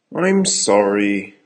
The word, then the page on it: I'm sorry: American English